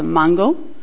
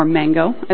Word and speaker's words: Mango: I'll be right up in the north in a city called Mango, or Mango, as we would pronounce it in English.